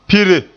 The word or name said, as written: pire